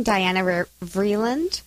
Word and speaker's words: Diana Vreeland: Mine's from Diana Vreeland.